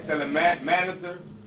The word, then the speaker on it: Shalmaneser: Shalmaneser.